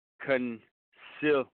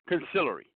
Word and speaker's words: consigliere: Today's word of the day is consillery. Consillery. Consillery is spelled C-O-N-S-I-G-L-I-E-R-E. Consillery. Consillery is a noun, and it means a counselor or an advisor.